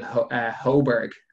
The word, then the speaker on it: Højbjerg: Someone that Spurs have gone out and got, Pierre-Emile Højbjerg. Is that how you pronounce his surname? – Yeah. – Højbjerg.